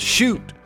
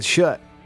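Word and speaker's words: Schutt: He says the helmet's pronounced shoot. I always said shut. Like, shut the door. Guess I've been wrong all these years.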